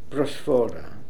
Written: prosphora

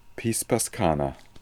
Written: Pispascana

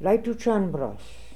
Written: Leitoochanbros

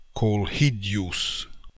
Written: Kolhidious

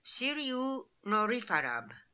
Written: Sirioonorifarab

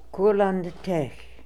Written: Kurlandtech

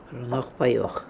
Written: Prnokhpaioch

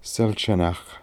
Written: Selchanakh

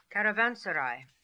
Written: Caravanseray

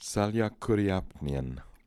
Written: Saliakooriapnian

